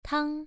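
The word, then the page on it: tang: tone 1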